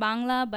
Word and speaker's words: Bangla: So we pronounce it Bangla, but I guess in English you can say Bengali.